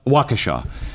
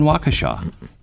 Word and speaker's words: Waukesha: And you know, we saw it in, what is it, Washaga? No, how do you say the name in? – Waukesha. – We saw that in Waukesha.